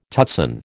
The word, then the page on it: tutsan: American English